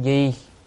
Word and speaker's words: Di: Còn chữ Di này có một cách đọc khác là đọc là Dị. Nếu đọc là Dị thì là Dạ, thì Dạ Thưa Thế Tôn đó. Đại Từ Thính Sát, thì hai cách đọc này đều được, Di hay Dị thì được, nhưng mà thông thường người ta đọc là Di.